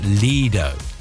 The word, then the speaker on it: lido: Yes, the Italian, which is where the word comes from, is, I believe, pronounced Lido. So technically, that's the correct way to say it.